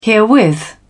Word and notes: herewith: British English

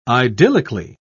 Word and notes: idyllically: British English

